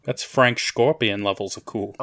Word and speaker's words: Schorpion: His name was Frank Scorpion. But it was like S-C-H-O-R-P-I-O-N. So you have to say it like Sean Connery.